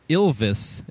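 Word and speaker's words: Ylvis: This is called Work It by Ilvis. It's like Elvis, but it's spelled with a Y at the beginning.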